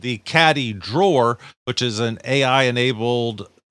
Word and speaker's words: CADDi: Caddy.